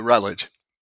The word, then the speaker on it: Routledge: Did I pronounce that correctly? Is it Routledge? – Routledge.